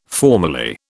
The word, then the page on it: formerly: British English